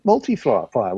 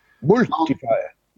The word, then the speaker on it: MulteFire: You, you need, James, you need to be very careful about how you pronounce that in, in Holland um, because it sounds like a really good chain of cake shops. MulteFire.